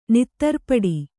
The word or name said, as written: ನೆತ್ತರ್ಪಟ್ಟೆ